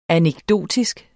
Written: anekdotisk

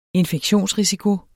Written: infektionsrisiko

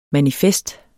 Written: manifest

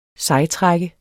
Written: sejtrækker

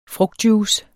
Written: frugtjuice

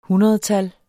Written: hundredtal

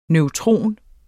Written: neutron

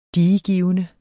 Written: DIEGIVENDE

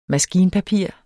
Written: maskinpapir

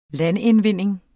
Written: LANDINDVINDING

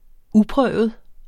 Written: uprøvet

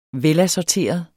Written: velassorteret

